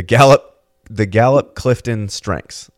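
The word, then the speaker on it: Gallup CliftonStrengths: So you use this tool called, and I hope I don't butcher the pronunciation, the Gallup Clifton Strengths.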